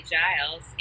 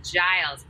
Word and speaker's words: Giles: At Hailey Giles, H-A-I-L-E-Y. It's like the precipitation plus an E-Y, so H-A-I-L-E-Y. – Okay. – G-I-L-E-S. Giles. It's a soft G.